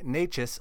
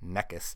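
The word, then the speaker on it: Nečas: For our top forward streamer pick of the week, it's Martin Natchez of the Carolina Hurricanes. I'm glad I looked up how to pronounce his name, because that is vastly different from Martin Neckes.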